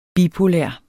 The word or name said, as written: bipolær